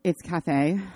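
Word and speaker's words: Caphe: I'm not going to say them right now because I don't know them, but it's Cafe. I believe it's pronounced, but it's C-A-P-H-E.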